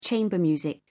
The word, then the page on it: chamber music: British English